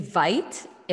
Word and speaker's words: Veit: My name is Nikki Veit. It rhymes with might. That's the new thing I've been saying because no one knows how to pronounce my last name. So Nikki Veit rhymes with might.